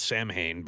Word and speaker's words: Samhain: It's the Feast of Samhain, which is spelled Samhain, but for whatever reason it's pronounced Samhain.